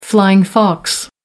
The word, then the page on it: flying fox: American English